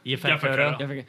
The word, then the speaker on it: Yaphet Kotto: Yaphet Kotto.